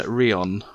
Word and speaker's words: Rheon: Iwan Rheon. So it's Welsh. Rheon, I think.